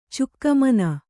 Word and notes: ಚೌಕಿಮನೆ: kannada

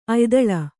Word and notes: ಅಯ್ದಾಳೆ: aydāḷe

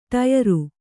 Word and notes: ಟಯರು: ṭayaru

